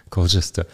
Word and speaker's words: Colchester: Growing up in Colchester is how you pronounce it, Colchester.